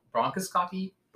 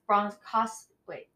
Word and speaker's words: bronchoscopy: I always say it wrong. Bronchoscopy, something. They stick a camera. – Oh, it's bronchoscopy. No.